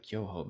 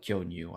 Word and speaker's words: Kyoho: And somebody asked, "Oh, do you like Kyoho?" And I said, "Oh yes, I do. Yeah, I like Kyonyu. I like Kyonyu." And I wasn't saying it correctly.